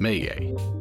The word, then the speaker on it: Maye: The name of this winery is pronounced Maye, and the meaning of the word is Native American for bird.